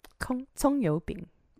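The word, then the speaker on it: Kong Yu Bing (葱油饼): Marco Polo had something called, watch me butcher the name of this, Kong Yu Bing? – Kong Yu Bing. – Okay, cool. Mm-hmm.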